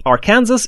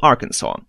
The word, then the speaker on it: Arkansas: И долгое время было как раз два варианта произношения. Был Арканзас и был Арканзо. Это было вызвано тем, что, как вы знаете, Америку осваивали много разных народов из многих разных стран, и главными среди них, конечно, были англичане и французы. Так вот, произношение Арканзо как раз пошло от французских колонистов, и до конца 19 века в обиходе было два варианта произношения.